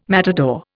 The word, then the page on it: matador: Indian